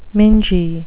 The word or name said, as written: mingy